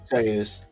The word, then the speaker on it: Soyuz: And the controls on their Soyuz spacecraft are all in Russian. Or I don't know how to pronounce that. – Soyuz.